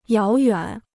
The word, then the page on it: 遥远: yáo yuǎn